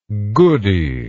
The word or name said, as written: goodie